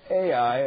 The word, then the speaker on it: Ai: spelt slightly differently, Hai here, but it's Ai, as we have taken the Cambridge Bible pronunciation for ease, Ai on the east.